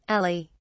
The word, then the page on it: ali: British English